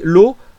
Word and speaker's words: Low: Et, Greg, et Maxime nous dit L'eau pour la sensation d'espoir que l'on éprouve avec une héroïne à la lecture, malgré tous les aléas de cette aventure. Ça s'écrit comment L-O-W.